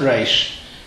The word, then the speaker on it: resh: So hadavorim, ho'eile, stress on the aleph, asher, how you pronounce a resh is up to you, but as I said in the previous talk here, that there is no such thing in Hebrew as a silent resh.